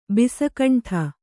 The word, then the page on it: ಬಿಸಕಂಠ: bisa kaṇṭha